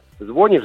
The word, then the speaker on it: звонишь: Да, а звонишь, это пока единственный возможный вариант. Не хочет наш народ признавать ударение «звонит», я имею в виду грамотную, образованную часть. наших соотечественников, носителей русского языка, не хотят грамотные люди, ударение «звонишь», «звонит», видеть в нормативных.